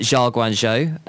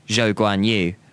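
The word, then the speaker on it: Zhou Guanyu (周冠宇): We obviously had the confirmation that Zhao Guanzhou, as the terrible pronunciator for the terrible track announcer at Austin, pronounced his name as. It is, to be clear, Zhou Guanyu.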